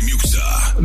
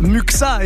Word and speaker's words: Muxxa: Ok, la prononciation c'était pas top, mais tu viens de massacrer la prononciation de mon nom. On peut dire qu'on est à un partout. C'est DJ Muxa. Muxa et pas Musca.